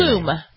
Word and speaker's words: Klum: Klum.